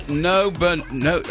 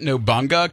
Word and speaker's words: Nobunaga: If today's your birthday, you share it with some people who are a pretty big deal, starting with Oda Nobunaga. I always pronounce it Nobunga, because it's the guy, it's Nobunga.